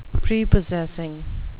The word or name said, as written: prepossessing